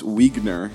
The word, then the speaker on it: Wiegner: That's from our friend Bruce Wiegner. He said his last name is pronounced like Wiener, but with a G.